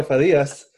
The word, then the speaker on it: Farias: Did I pronounce that right? – Farias. – Diego Farias, who's the co-founder and CEO of Amuse, joining us for episode 4 of the L's, aka Lessons Learned.